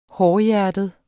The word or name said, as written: hårdhjertet